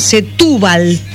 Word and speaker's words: Setúbal: En una laguna que se llama, espero pronunciarlo bien, Setúbal, con acento en la U. – Sí.